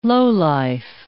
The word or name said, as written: lowlife